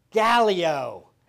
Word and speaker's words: Gallio: But Gallio, and again, I could argue on how you pronounce this one. How did you say it, Mark? Gallio. I said it that way. I know I did.